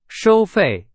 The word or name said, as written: 收费